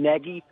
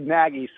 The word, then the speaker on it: Nagy: First of all, he pronounces his own last name Nagy. but I think the team prefers to say Nagy, so it'll rhyme with Swaggy.